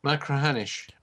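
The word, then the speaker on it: Machrihanish: Macrahanish.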